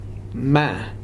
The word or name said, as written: mæ